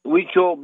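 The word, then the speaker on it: Huichol: Also, Christopher Villa at Weecho Beadwork, Native Jewelry Handmade.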